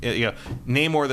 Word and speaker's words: Namor: Namor the Submariner is more or less how Stan Lee said it. – Yeah. – But you could say it Namor, you know, all that stuff.